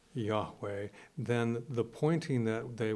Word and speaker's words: Yahweh: How we would pronounce it, yeah. – Yahweh. – Yahweh.